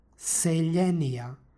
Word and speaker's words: seljenija: stihu, pročitali smo seljenija sa L u osnovi prema ekvivalentu u srpskom jeziku.